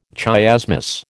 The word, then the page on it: chiasmus: American English